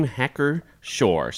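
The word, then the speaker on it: Hacker-Pschorr: The name Hacker Shore. So it's Hacker dash Pschorr. And I would not have known how to pronounce this unless Mr. Spees told me how to pronounce it.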